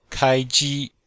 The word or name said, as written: kai1ji1